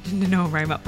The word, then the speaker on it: NaNoWriMo: Is it NaNoWriMo? NaNoWriMo? – NaNoWriMo.